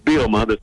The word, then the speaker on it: Bielema: Is it Bielema? That's the proper way to say it, isn't it? – It's pretty close. It's Bielema.